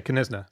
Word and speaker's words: Knysna: Okay, Knysna.